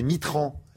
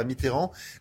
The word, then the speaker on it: Mitterrand: C'est drôle parce que, comme autrefois, quand on était un peu très à droite, on disait Mitran et non pas Mitterrand.